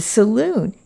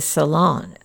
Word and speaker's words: saloon: The saloon is pronounced in the English way instead of the salon of the French.